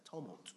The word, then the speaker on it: tumult: So when he could not ascertain the truth because of the tumult, how do you pronounce that? Tumult. There you go.